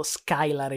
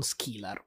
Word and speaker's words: Skylar: Se vince Miami, però mi devi fare un fioretto devi cominciare a pronunciarlo Skylar e non Skillar,